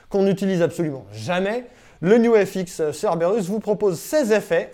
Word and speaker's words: Nux: Alors, on prononce New FX, hein, c'est comme ça, c'est comme ça qu'on le fait.